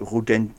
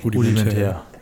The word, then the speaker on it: rudimentär: Ist ja okay, nichts gegen Praktikanten, aber die Antworten waren sehr rudiment, rudent, ich kann es nicht mehr aussprechen. – Rudimentär.